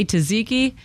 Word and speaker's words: tzatziki: It's spelled T-Z-A-T-Z-I-K-I.